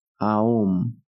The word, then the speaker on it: Aum: Este mantra se escribe con una U, pero se pronuncia como una O, es decir, Aum, alargando cada letra así.